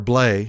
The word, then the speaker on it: Blais: And you can saw it's not Sammy Blais. He's French. It's Sammy Blais, or Blay, however you want to pronounce it. The S is silent.